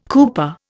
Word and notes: cooper: Indian

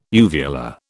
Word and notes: uvular: British English

